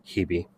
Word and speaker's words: Hebe: which I actually have heard it pronounced. It sounds weird, but Hebe.